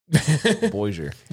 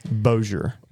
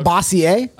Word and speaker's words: Bossier: How do you say that one? – Bossier. – Boozy. – Bozier. – Bozier. – I was closest. – It's not Bossier? – No.